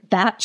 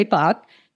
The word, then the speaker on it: Bach: Rescue Remedy is a Bach flower essence, or as I've learned, Bach flower essence. That's how you pronounce his name. – Yes. – And even though most of us say Bach.